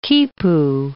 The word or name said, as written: quipu